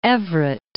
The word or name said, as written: Everett